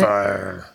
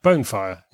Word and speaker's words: Banefyre: Track 5, is that how we pronounce it? – Banefyre. – Yeah? – Yeah, Banefyre, yeah.